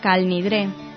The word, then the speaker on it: Kol Nidre: Abrimos este espacio, como no podía ser de otra forma, con el Kol Nidre, tal vez la oración más emblemática del ritual judío. En ciertas comunidades se pronuncia Kol Nidre.